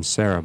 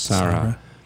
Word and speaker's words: Sarah: I kept saying Sarah, but it's actually, I guess, pronounced Sarah.